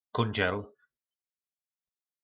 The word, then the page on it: kondzel: po našimu